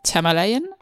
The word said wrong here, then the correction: Shyamalan: M, Night Shyamalan. – M. Night Shyamalan.